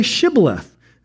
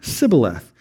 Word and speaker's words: Shibboleth: And when he said no, they said to him, then say Shibboleth. And he said, Sibboleth, for he could not pronounce it right.